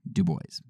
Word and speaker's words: Du Bois: And it says that Du Bois actually pronounced his own name Du Bois.